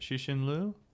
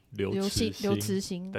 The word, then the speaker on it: Liu Cixin: How do you pronounce it? Xixin Liu? – Liu Cixin.